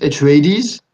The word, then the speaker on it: Atreides: I think they pronounce it Atreides in the film.